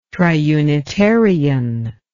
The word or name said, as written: triunitarian